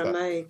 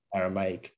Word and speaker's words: Aramaic: And that's Aramaic, or some will say Aramaic. I can't pronounce Aramaic. – That. Aramaic, good.